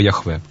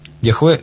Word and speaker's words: Яхве: Есть две традиции прочтения этих букв. Либо Иегова, либо Яхве. Яхве – более научная традиция, Иегова – более традиционная.